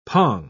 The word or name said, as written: pong